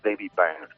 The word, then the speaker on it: David Byrne: Yo tengo un gran referente en la música que me gusta, que es David Byrne, uh-huh. el cantante de los Talking Heads. – Sí. – Entonces, cuando... ¿Has visto cómo lo he pronunciado? – Impresionante. – Para mí, espectacular.